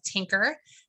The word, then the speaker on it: Tynker: So we, right now, my son really likes a website and an app called Tinker, and it's spelled T-Y-N-K-E-R.